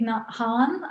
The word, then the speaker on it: Nhat Hanh: I would say Thich Nhat Hanh, I think the Buddhist dude, the Buddhist teacher.